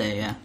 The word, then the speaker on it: Kitase: So, Joystick had a talk with Square Enix producer Kitase? Is that how you pronounce it? – Kitase, yeah.